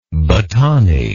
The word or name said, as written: battani